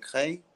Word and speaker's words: Creil: Uh, we were in the outskirts still of France, so we were in in Creil. They pronounce it Cré, mm.